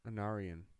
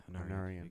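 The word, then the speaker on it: Anárion: Anarion. – Yeah, Anarion.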